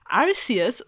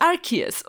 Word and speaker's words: Arceus: Die ursprüngliche englischsprachige offizielle Aussprache war Arceus und das musste man abschaffen, weil Ars ist ein anderer Wort für Hinterteil. Und deswegen heißt es jetzt Arceus und im Deutschen ist es auch Arceus.